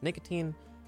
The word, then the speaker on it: Nikatine: Enovan is spelled E-N-E-V-U-N. – Awesome. Well, thank you so much, Enovan. My name, of course, is Nicotine. That's N-I-K-A-T-I-N-E.